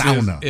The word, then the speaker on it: sauna: I think if you have to be a Scandinavian to say that. – I think that's sauna. Is technically how they pronounce it.